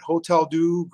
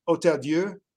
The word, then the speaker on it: Hôtel-Dieu: Most people will pronounce it Hôtel-Dieu Grace Healthcare. For our Francophone audience, it's Hôtel-Dieu.